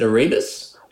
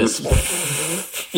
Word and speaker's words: Erebus: I'll put a bunch of links up so people can check out both bands and the, it's the new single, is it Erebus? – Yeah, Erebus, yeah. – Oh, Erebus. – Yeah, that's right. I think that's how you say it.